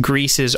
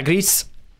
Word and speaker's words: gris: the s is pronounced interestingly enough um cool. so there you but it does still just mean gray um okay. but yeah gris and eh,